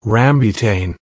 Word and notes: rambutan: American English

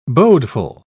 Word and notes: bodeful: British English

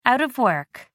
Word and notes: out of work: American English

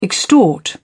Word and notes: extort: British English

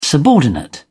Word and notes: subordinate: British English

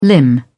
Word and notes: limb: British English

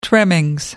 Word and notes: trimmings: British English

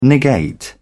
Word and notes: negate: British English

